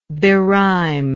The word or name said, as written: berime